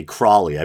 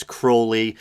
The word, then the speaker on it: Crowley: A lot of people say Crowley. I believe the proper pronunciation is Crowley.